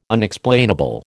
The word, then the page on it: Unexplainable: American English